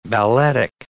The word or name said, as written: balletic